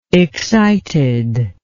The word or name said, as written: excitedness